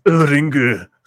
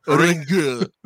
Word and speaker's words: Ringu: It's pronounced Ringu. – Ringu. In Ringu.